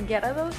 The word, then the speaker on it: Gyarados: ¿tienes Sí, este, yo voy a hablar de Magica o Gyarados, ¿sí se pronuncia así? Sí. Sí.